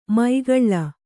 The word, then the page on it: ಮೈಗಳ್ಳ: Kannada